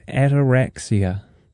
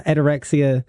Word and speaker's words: Ataraxia: It is called Ataraxia. – That's right. – I hope I'm saying that right. – Yeah, you are. Ataraxia.